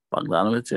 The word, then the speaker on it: Bogdanović: Because Frank is very much a guy that if, you know, Bogdanović, am I saying that right? – Bogdanović, yeah.